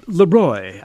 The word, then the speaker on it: Leroy: Leroy, that's how you pronounce it.